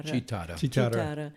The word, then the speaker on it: chitarra: And they used this thing, I don't know how you pronounce it, whether it's a guitarra or chitarra. – Chitarra.